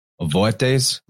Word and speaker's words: Bootes: I'm also still crunching on that Bootes in Greek would be pronounced Votes, like the B. They don't do B in ancient Greek. It's a V sound.